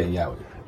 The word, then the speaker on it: yaoi: Can you help me with this pronunciation? – Yaoi? – Yaoi? – Is it? – Y-A-I-O? – Yeah, Yaoi.